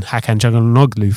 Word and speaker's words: Hakan Çalhanoğlu: And but the interesting thing is they've also already signed his replacement in Hakan Çalhanoğlu from, great pronunciation.